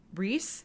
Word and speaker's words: Reiss: It's called the Nanette dress by the brand Reiss. I think that's how you pronounce it. R-E-I-S-S. Yep.